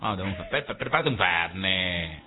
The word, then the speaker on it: Fernet: Ay, pero queda tan bien el Fernet, con acento en la última E, porque es aguda y termina en vocal, yo acento.